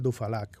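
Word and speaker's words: Duphalac: A ver, te puedo decir, no, el histórico de este tipo de laxantes eficaces es el Dufalac o el Dupalac, se escribe con PH.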